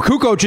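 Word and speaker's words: Kukoč: No, Kukoc is the way that it is, but people would go, yeah, so I'm watching the game last night and Kukoc.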